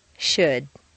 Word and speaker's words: should: Once you've found all the L sounds, the good news is that very often you don't even have to pronounce them. Read the following list of words after me. 1. Would, could, should.